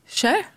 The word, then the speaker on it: Cher: Hur uttalar du den här artisten? – Kör. – Precis.